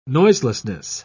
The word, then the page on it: noiselessness: British English